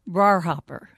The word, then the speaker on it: Briar Hopper: Well, actually, it's pronounced in Kentucky, Briar Hopper.